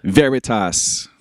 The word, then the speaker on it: Veritas: Veritas.